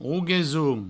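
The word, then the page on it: Rogesons: Rouguesoun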